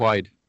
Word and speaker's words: Quaid: Niin siinä oli tämä näyttelijä Dennis, en osaa lausua, Guide. Miten se lausutaan? – Dennis Quaid. Dennis Quaid?